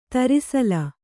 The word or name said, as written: ತೆರೆಸೇಲೆ